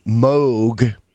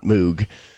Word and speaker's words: Moog: The things you make audio with, starting back in the late '60s, used to be modular, and then Moog. And yeah, that's how you say it. It's not Moog.